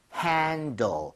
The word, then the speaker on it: handle: HANDLE, handle. 이게 바로 맞아. – 손잡이가 되겠습니다. – 네. – 발음할 때도요, L 발음 좀 신경 써서 L 발음이 혀끝이 윗니 입천장 사이 앞쪽으로 가야 됩니다.